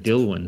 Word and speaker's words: Dilwyn: D-I-L-W-Y-N. Dilwyn. It's a pretty cool name, isn't it? – It's not a common name. It's not a common here, is it? Dilwyn.